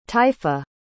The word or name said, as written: taifa